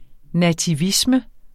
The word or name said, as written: nativisme